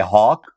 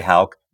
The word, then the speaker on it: Hauck: So many people don't. They say Hawk, but of course you know who. We've known each other for so long, and you say Hauck beautifully.